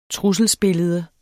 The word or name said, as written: trusselsbillede